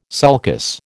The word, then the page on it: sulcus: American English